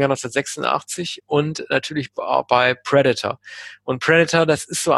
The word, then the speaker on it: Predator: Oder wie Kinder, wie ich es damals waren, nicht Predator, sondern Predator gesagt haben. Man hat es damals mal falsch ausgesprochen in Deutschland. Predator mal gesagt. Aber er heißt tatsächlich Predator, das Haupttier. Ja, also immer Predator, ne, weil das immer die Silbe, die erste Silbe falsch betont.